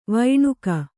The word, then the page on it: ವೈಣಕ: Kannada